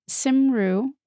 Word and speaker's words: Cymru: ITV Cymru Wales. It's hard to pronounce because it's spelled C-Y-M-R-U, but I think I got there. – And it's never pronounced the way you think it is because it's Welsh.